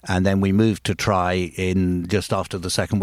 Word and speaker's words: Traigh: It's spelled Traigh, it's pronounced Traigh, and it means beach in Gaelic. So it's a Gaelic word.